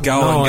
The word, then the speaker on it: Gall: Also, for reference, the shop is spelt G-A-L-L. – Hull and Hull. – But Kieran's just an expert pronunciator. – Yeah, I mean, Hull and Hull. G-A-L-L. – Gal. – It's Gal. Yeah, it's basically Gal and Gal.